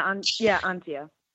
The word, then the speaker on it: Antje: So I got Antje right. I got your name right, correct? – Yeah, Antje. – Okay. Everyone, it's not pronounced the way it's spelled, just so you know.